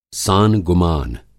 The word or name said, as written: सानगुमान